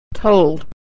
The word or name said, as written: told